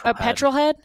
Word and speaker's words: petrol head: So far, being a patrol head, which if anyone doesn't know what that means, it means you're really into like cars and automotives. So far, petrol head. – Did I say it wrong? – Yeah. – Petrol head.